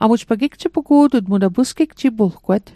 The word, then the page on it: pusgigjipulgwet: Mi'gmaq